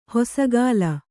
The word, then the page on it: ಹೊಸಗಾಲ: hosagāla